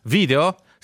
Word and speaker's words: video: Video. Stessa storia, viene dal latino video, che significa vedo, io vedo, pronunciatelo all'italiana.